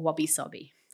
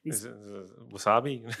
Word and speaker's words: wabi-sabi: No, wasabi. Wabi-sabi. So it's actually spelt okay. W-A-B-I. Actually, it's spelled in English. Yeah. Obviously, it's spelled in sort of English letters. Okay. W-A-B-I-S-A-B-I. But okay. It said wabi-sabi.